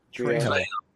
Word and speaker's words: Trejo: Trejo.